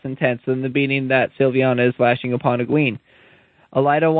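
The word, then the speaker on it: Silviana: Tarna reports that Egwene spends half her days with Sylvana. It's Sylviana or Sylvana? – Sylviana. – Yeah. Then I've been saying it wrong, too bad.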